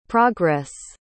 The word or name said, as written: progress